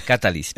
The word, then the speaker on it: Catalis: Catalis. Con el acento en la A. – En la primera A.